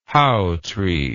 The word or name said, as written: hautree